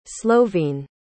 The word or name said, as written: slovene